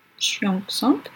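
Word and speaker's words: Xiong Song: Le Xiang Sang, j'espère que je le dis bien, Xiang avec un X, X-I-O-N-G-S-O-N-G, Xiang Song.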